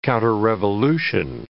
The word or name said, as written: counterrevolution